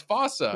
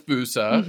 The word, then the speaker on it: fossa: And I used to laugh thinking, no, it's fossa. – Right. – But you're telling me it is fusa. Mm-hmm. It's just we pronounce it different in the U.S.